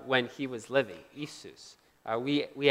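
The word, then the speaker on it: Isus (Иисус): Anyone want to try to pronounce this word? Anyone? Isus, yeah, very good, Isus.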